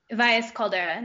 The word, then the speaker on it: Valles Caldera: During college, she spent her summers working for the Bosque Ecosystem Monitoring Program, researching American pika in the Valles, how do you say it, Valles Caldera? – Valles Caldera National.